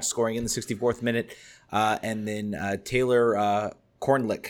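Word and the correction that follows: Kornieck: I think I'm pronouncing that right. Korniak, sorry. I thought that was an L. Korniak, thank you.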